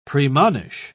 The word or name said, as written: premonish